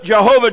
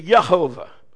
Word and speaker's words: Yehovah: But in Hebrew, there's no J. It's pronounced like a yo. So it wouldn't be Jehovah, it'd be Yehovah.